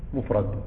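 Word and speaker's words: mufrad (مفرد): Ou le mot mufrad, c'est mim, fa, ra, del, mufrad. Comme ça se prononce, mufrad, mim, fa, ra, del. Hein?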